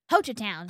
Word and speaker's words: Hochatown: Hochatown.